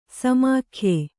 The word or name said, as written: ಸಮಾಖ್ಯೆ